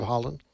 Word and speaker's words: Haaland: Her last name is pronounced, Deb Haaland.